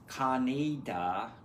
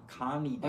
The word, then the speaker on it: Kaneda: It's Kaneda. Kaneda. – Right, just to talk about the Kaneda. – Book. – Kaneda.